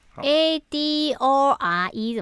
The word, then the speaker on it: Adore: A D O R E 怎么念啊？Adore。